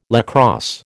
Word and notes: lacrosse: American English

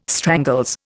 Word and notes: strangles: Indian

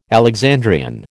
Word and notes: Alexandrian: American English

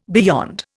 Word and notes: beyond: Indian

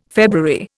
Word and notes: February: Indian

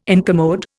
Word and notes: incommode: Indian

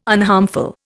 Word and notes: unharmful: Indian

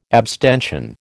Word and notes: abstention: American English

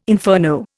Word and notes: inferno: Indian

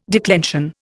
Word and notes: declension: Indian

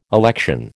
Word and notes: election: American English

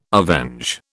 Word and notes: avenge: British English

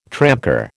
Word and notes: tramcar: American English

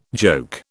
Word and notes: joke: British English

